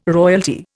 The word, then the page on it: royalty: Indian